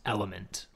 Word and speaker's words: LMNT: Reminder, LMNT is pronounced Element.